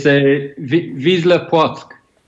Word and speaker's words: Wisła Płock: Wilsa Pluck? – Yeah, to be fair, that's how I would have said it when I first came over. So it's Wisła Płock.